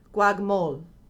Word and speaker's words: Guagmolt: Fabrice Guagmolt. No, that was terrible. No, Guagmolt. But they don't pronounce the T in French, so Guagmolt.